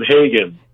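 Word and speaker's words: Haggen: He pronounces it Hagen. – Yeah, well, oh, really? It's H-A-G-G-E-N. Yeah. All right, so I'd go with Hagen, but okay.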